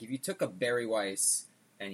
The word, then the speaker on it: Weiss: Weiss is the German word for white, and it is a style of beer. And, you know, in Germany they pronounce their W's as V's, so.